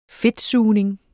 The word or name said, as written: fedtsugning